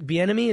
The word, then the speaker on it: Bieniemy: Like right now, what is it, Eric Bieniemy?